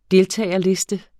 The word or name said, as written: deltagerliste